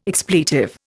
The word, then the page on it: expletive: Indian